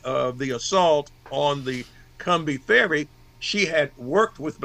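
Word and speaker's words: Combahee: Let's use the word that and pronounce it the way the folks down that way. So three small ships left Beaufort and headed for the Cumbie.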